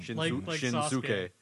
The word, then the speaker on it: Shinsuke: Hey, I used to be bad at pronouncing Japanese names too, but then I realized that a lot of them the U is silent. So that's you just got to say it fast. It's like Shinsuke Nakamura. You wouldn't think there was a you in Shinsuke, but there is.